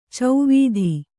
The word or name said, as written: ಚೌವಿಧ